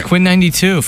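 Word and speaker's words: Quinn XCII: So it's Quinn 92 for everyone. – Quinn 92. – So it's spelled XCII, which can be misleading.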